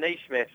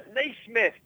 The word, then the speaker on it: Naismith: And at Jack Archer, when Brits say Stephen Naismith scored a goal, it sounds like you're saying Stephen A. Smith scored a goal.